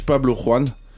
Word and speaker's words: Pablo Juan: Euh, Brodinski a sorti un, nouvel album avec Udrich, Pablo Juan, avec l'accent, s'il te plaît.